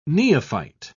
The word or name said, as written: neophyte